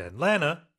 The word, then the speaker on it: Atlanta: And pronunciation, usually it's just pronounced Atlanta, right? Instead of Atlanta. – 그렇죠. 이건 한국식 발음이니까. – Yeah. – 네. Atlanta, 뭐 미국식으로 말 Atlanta. 그래서 세 개의 음절이에요. 우리는 Atlanta, 사음절로 한국식으로 하지만 영어로는 삼음절.